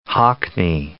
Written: Hockney

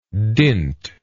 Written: dintless

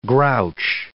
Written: grouch

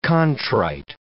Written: contrite

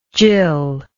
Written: jill

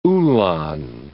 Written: uhlan

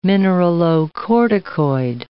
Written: mineralocorticoid

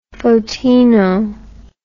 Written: photino

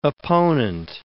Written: opponent

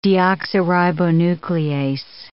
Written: deoxyribonuclease

